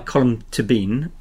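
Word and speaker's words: Colm Tóibín: And the last book I was going to mention is The Magician by Colm Tibbin. I'm told that's by my Irish friends, that's how you meant to pronounce his name. Although I never used to pronounce it like that.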